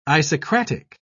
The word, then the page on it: isocratic: British English